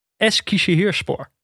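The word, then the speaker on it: Eskişehirspor: En, nou, dat was, nou ja, dat was Alti Altinordu tegen Eskishehirspor. – Keurig. – Zeg ik het goed? – Keurig, zeker, zeker, zeker.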